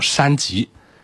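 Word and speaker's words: 栅极: 句号没有了，就只有这一句话对应的这个词叫山极，然后一个木字旁加一个工作手册的册组在一块儿，在这儿它才发音发山，叫山极。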